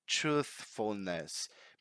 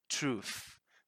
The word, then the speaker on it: truthfulness: Because now we have the voiceless TH linked to the F letter. So, because sometimes people are trying to pronounce the voiceless TH, but they end up pronouncing F like truth. But that's not right. Truth. That's not right. The what is right is truth.